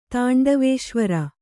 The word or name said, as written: ತಾಂಡವೇಶ್ವರ